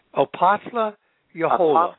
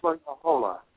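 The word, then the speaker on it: Apothle Yahola: And Yahola. Is that how you pronounce that? – Opatla Yahola. – Opatla Yahola. – Yeah.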